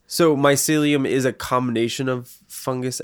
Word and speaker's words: mycelium: I had a question about how do you say the word again? Mycelium? – Mycelium.